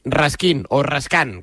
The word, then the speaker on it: Raskin: es Raskin, que es como se pronuncia,